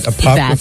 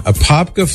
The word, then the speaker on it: Apopka: And Jeanette in Apopka. I hope I'm saying it right, Mary. Does that sound right? Apopka, Florida? – That sounds perfect to me. – Right on. Apopka, Florida.